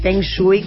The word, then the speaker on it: feng shui: Que es la primera vez que escucha en un medio a alguien pronunciar la palabra Feng Shui correctamente.